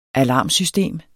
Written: alarmsystem

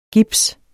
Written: GIPSEN

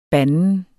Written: banden

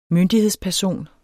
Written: myndighedsperson

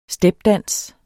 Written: stepdans

